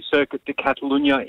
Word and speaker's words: Circuit de Catalunya: A lot of the teams are bringing a lot of aerodynamic parts upgrades to the Circuit de Catalunya in Barcelona, if you wanted me to pronounce it that way, Michelle.